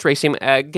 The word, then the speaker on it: raceme: So that's raceme.